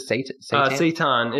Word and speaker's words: seitan: Seitan. It's like. – Seitan, that's it, yeah.